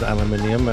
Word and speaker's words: aluminium: Hi there, Jeff, and all this aluminium that's going on, and the correct pronunciation is aluminium, and you guys latched on to an incorrect spelling and pronunciation. That was not the discoverer's intent, and for some reason you've hung on to it.